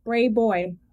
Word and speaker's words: Brayboy: B-R-A-Y-B-O-W. Alicia Brayboy. – Brayboy. – What did I say? – B-R-A-Y-B-O-Y.